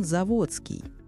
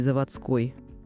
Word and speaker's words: Заводский: Все же кеменовчане знают, что ударение ставится на букву «О». А знаете почему? Название крупнейшего района города – происходит от старого сибирского выражения «за водой». Для жителей правобережной деревни Кемерово эта территория находилась за водой, за Томью. Поэтому исторически ударение ставилось на «о» – «заводский», а не «заводской».